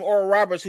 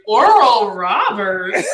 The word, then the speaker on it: Oral Roberts: I was like, Oral Roberts. Like, either he's punking me or I cannot pronounce that name correctly. – No, you said it right.